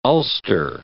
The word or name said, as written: Ulster